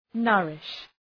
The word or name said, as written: nourish